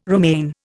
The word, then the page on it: romaine: Indian